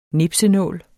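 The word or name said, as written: NIPSENÅLE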